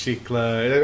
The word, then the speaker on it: Shiklah: Like, well, I mean, the entire premise of why like Deadpool even meets Sheikla, am I saying that right? – Yes.